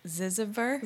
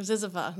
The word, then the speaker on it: Zyzzyva: Zizover. – Zizover. Or Zizova. Zizover. Can I spell it for you? – Yeah, actually. – Z-Y-Z-Z-Y-V-A. Zizova. – Zizova.